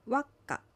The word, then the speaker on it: ワッカ: ワッカは水という意味です。アクセントはワにあります。